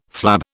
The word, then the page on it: flab: British English